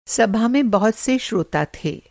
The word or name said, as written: श्रोता